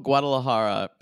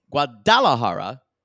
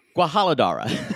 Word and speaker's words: Guadalajara: So we have a couple of Guadalajara pronunciations. Guadalajara. From Guadalajara. – Mm-hmm. – Guadalajara. Then one of her, either her sister or her mother or her friend says Guajaladara.